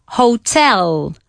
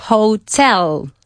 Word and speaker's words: hotel: La sílaba acentuada aquí es la segunda, ¿vale? Uh-huh. En castellano es la primera. No, en castellano es la segunda, ¿no? Hotel. Y en inglés también es la segunda. Hotel, hotel.